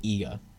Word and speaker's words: Iga: I think they just pronounce it Iga. It's IGA, and I believe the inflection goes on to IGA.